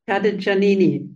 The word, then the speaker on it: Cadicciannini: Der Anlass war natürlich auch dein Buch, was gerade erschienen ist, Familie am Tisch, das du gemeinsam mit Georg Cadicciani geschrieben hast. Habe ich es richtig ausgesprochen? – Cadicciannini. – Cadicciannini, alles klar.